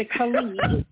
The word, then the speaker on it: KAHLEA: the KAHLEA.